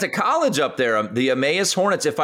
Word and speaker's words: Emmaus: I don't want to mispronounce the town. Emmaus, Pennsylvania. – Emmaus. – Yes. Thank you.